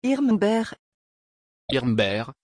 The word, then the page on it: irmbert: French